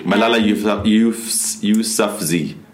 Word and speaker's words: Malala Yousafzai: Malala Yousafzai.